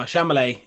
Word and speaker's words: Chalamet: Timothy Chalamet, or Chalamet? Am I saying that right, guys? – Chalamet ding dong. No, Chalamet, yeah.